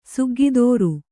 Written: ಸಗ್ಗದುರು

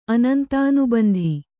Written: ಅನಂತಾನುಬಂಧಿ

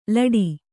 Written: ಲೋಟ

